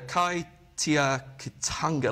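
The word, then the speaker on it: Kaitiakitanga: They call it the Kai Tia Kitanga license. I'm probably butchering the pronunciation there. And how you spell it then? – KAITIA KITANGA. – Okay.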